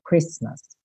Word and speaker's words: Christmas: Mas aqui, então, little Christmas, como já vimos na semana passada, o T não é pronunciado, Christmas, ok?